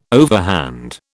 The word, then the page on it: overhand: British English